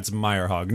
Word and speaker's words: Myrehaug: It's Myrehaug. Norwegian.